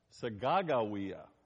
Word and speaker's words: Sacagawea: But Sacagawea is apparently the way that it's supposed to be pronounced.